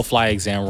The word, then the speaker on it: Fly Exam: It sounds dumb, but the title Fly Exam rhymes with eye exam.